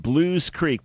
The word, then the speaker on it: Belewes: This is pronounced Blues Creek, but spelled B-E-L-E-W-E-S, but pronounced Blues.